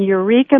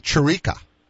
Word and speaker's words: Eureka: Yeah, we are in Eureka, Missouri, which is a southwest suburb of St. Louis. – Okay. All right. You're in Chirica. That's how they say it up in northeastern South Dakota.